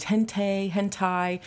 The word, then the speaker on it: hentai: And I always pronounce this wrong. It's hentai.